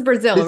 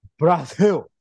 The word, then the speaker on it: Brazil: This is Brazil, right? – This is Dragon, you pronounce. How dare you pronounce it like a gringo? It's pronounced Brazil, which is how Vin Diesel says it in the movie.